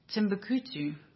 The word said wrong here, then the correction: Timbuktu: Timbuktu. How do you say it? Timbuktu. That's it.